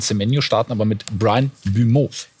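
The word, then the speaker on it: Mbeumo: Brian, ausgesprochen Bumeau, das ist ein Flügelspieler.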